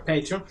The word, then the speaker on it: Patreon: No, pero ahorita lo has dicho bien las dos veces. Bueno, Patreon.